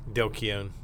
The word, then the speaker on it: Keown: Dale Keown.